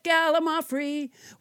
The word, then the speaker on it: gallimaufry: Let's never forget gallimaufry, which I pronounced wrong until nobody James Hyder corrected me.